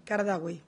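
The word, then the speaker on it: Qaradawi: ¿Lo pronuncié mejor? Pronuncio fatal los nombres árabes y este me va riñendo. Kardawi. Pero se escribe Kadarawi.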